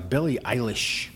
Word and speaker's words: Billie Eilish: Anyway, I saw a commercial with Billie Eilish.